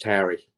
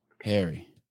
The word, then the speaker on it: Hari: Yes, it's H A R I, and it's just pronounced Harry. Harry.